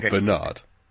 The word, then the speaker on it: Bernard: How many 007 films did Bernard Lee appear in as M? – Seven? – He appeared in 11. – And it's Bernard. – Bernard. – Okay. No, Bernard. He's English.